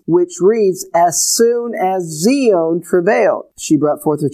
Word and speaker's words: Zion: which we pronounce as Zion.